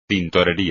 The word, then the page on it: tintorería: espagnol